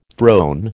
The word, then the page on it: throne: American English